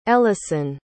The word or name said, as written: ellasyn